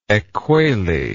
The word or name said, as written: ekuele